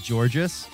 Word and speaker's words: Georges: By the way, I think Georges is just pronounced George. It's like an old school spelling.